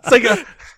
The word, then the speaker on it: saga: Saga. – I don't know. I heard it pronounced Saga or Sega. – Who says Sega? Yeah. No one says Sega.